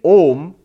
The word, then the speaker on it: Om: In Sanskrit grammar, when you put A and U together, it becomes O. So, to say Om is the correct pronunciation of Om. And in English, when you write O and M, it's correct. It's the exact pronunciation of Om.